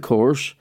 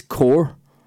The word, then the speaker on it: Corr: My dad would go, it's not pronounced that way. It's Corr. And I'd go, you're saying it the same as me. And he goes, no, listen, Corr.